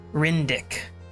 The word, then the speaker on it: Ryndik: His real name is Rydnik. – Sorry, I said that wrong. Rindick. – I'm emphasis on the dick. – R-Y-N-D-I-K.